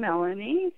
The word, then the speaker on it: Melanie: My first name, Melanie, is M-E-L-A-N-I-E.